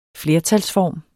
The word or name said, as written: flertalsform